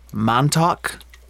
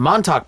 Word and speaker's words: Montauk: So did you know that Stranger Things, the show was originally called Montauk? I'm pretty sure that's how you pronounce it. M-O-N-T-A-U-K, right? – Mm-hmm.